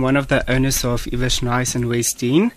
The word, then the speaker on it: Lewers Nice: And our name is Ivesh Nice. – So the L is silent.